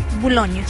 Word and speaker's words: Boulogne: Boulogne, sí.